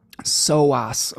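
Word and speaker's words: psoas: SOAS. Okay, thanks, Bonnie. Sorry, silent P in the beginning.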